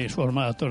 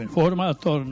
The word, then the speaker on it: informator: Znači, mislim, to je, dakle, ne informator, nego informator, ne regulator, nego regulator i tako dalje.